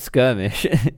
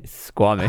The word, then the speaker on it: Squamish: Not skirmish, Squamish. – How do you spell it? Like S-Q... – U-A. – U-A-M-I-S-H.